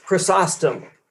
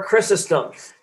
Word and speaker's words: Chrysostom: One is St. John Chrysostom, or Chrysostom, depending on, I've heard both pronunciations, so pick one.